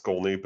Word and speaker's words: Schkolne: Yeah, I have my last name is, it's pronounced Schkolne, but the spelling is, I'm pretty easy to find online.